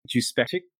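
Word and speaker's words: Juspeczyk: Adrian sees Laurie and he pronounces her last name. So now I know exactly how to pronounce her last name, right? Juspectic. I've never known how to say that, so now I do.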